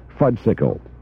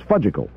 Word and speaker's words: fudgsicle: people in this area seem to say fudsicle. Meaning, it's spelled, by the way, F-U-D-G-S-I-C-L-E. So that would be fudsicle. Where I was brought up in the Buffalo area was fudgicle.